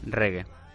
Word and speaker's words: reggae: ¿Se dice Riggy o cómo se pronuncia? Reggae. ¿Se pronuncia reggae? – Sí, bueno, pero es... Oh, Riggy, no sé, la es que no sé. Habría que poner voz anglosajona. – Reggae. – Reggae, reggae.